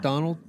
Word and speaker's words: Darnold: Darnold? – Yeah.